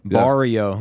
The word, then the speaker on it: barrio: Pronouncing like Spanish. Barrio. Yeah. – Barrio. – Barrio. For, we lived in the barrio. – Okay. B-A-R-I-O.